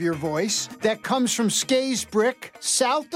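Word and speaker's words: Scarisbrick: So thank you for pronouncing it Skaysbrick, because that's how it should be pronounced, even though it's spelled Skaysbrick.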